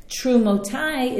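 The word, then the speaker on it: Trumotai (תרומתי): Trumotai is spelled Taf Resh Vav Mem Taf Yud without a Vav in between the Mem and the Taf. So this is one of those classic cases of there's the way it's written and the way our tradition, right, the way it's pronounced.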